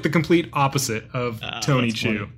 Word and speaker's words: Chu: They did like a spinoff with his sister, and it's called Chu, but it's spelled like their last name, C-H-U.